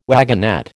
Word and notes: wagonette: American English

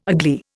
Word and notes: agley: Indian

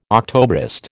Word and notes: Octobrist: American English

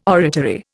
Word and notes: oratory: Indian

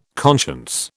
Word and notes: conscience: British English

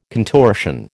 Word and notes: contortion: American English

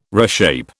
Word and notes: reshape: British English

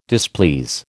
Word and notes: displease: American English

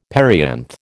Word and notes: perianth: American English

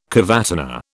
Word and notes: cavatina: British English

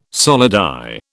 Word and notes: solidi: British English

